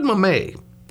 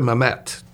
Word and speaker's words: Mamet: David Mamet. Or David Mamet, depending upon which way you want to pronounce it.